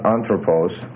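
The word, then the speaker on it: anthropos (ἄνθρωπος): Now one thing you're going to notice about a word like anthropos is the accent's going to shift. It starts out as anthropos, with the stress on the first syllable, and then when you get the genitive plural form that you have here, because the stress moves down the line and it's anthropon.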